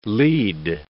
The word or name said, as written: lead